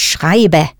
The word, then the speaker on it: schreibe: Richtig, ich schreibe. – Pronounce that E ending for the ich person clearly.